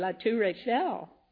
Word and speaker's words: La Tour Eiffel: Oh, she said, La Tour Eiffel.